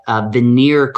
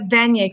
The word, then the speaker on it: Vanier: Is also an early childhood education professor at Vanier College. And I'll see if she... How do I pronounce it? – It's at Vanier College. – Vanier College.